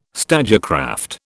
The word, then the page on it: stagecraft: British English